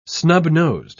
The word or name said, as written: snub-nosed